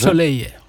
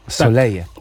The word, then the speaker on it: Soleil: A teraz posłuchamy utworu Soleil. Dobrze, czy? Dobrze? – Soleje. – Soleje, tak? No bo tutaj mamy wymowę związaną z wyspą Reunią.